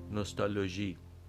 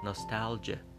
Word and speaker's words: nostalgia: تلفظ نادرست فارسی نوستالژی تلفظ انگلیسی البته اون چیزی که خیلی غالب هست ناستالژه و تلفظ بهتر فارسی